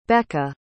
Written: becca

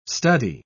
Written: study

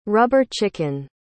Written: rubber chicken

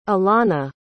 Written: ilana

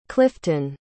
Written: clyftun

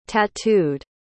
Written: tattooed

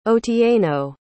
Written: otieno

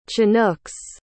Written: chinooks

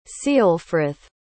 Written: ceolfrith

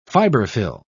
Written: fiberfill

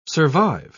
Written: survive

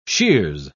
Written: shears

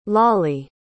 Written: lawley

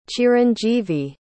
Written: chiranjeevi

